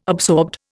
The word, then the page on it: absorbed: Indian